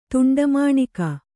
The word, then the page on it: ತುಂಡಮಾಣಿಕ: tuṇḍa māṇika